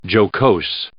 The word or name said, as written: jocose